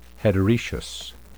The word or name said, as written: heteroecious